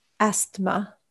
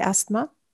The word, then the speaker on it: asthma: So like if you have asthma, mm-hmm. maybe, do you say asthma? – Like, asthma. – Asthma. – Yeah, it's a silent T.